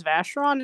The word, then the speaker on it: Vacheron: So, ironically, I was actually on a bit of a tangent consulting with someone who speaks, hopefully, a local language about the pronunciation. Apparently, it is Vacheron, and apparently it is French.